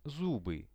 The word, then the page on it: zuby: Russian